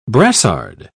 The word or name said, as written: brassard